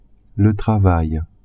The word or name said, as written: travail